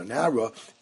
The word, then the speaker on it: Nara: Now, Nara is spelled without a hey. It's pronounced Nara as if it had a hey, but it's spelled without a hey. It's spelled as if it was Nar, but it really is pronounced Nara.